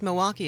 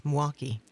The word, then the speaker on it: Milwaukee: Well, of course, Milwaukee is not Milwaukee. It's Mwaukee. – Mwaukee.